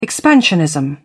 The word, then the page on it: expansionism: British English